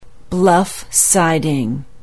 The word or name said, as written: bluff siding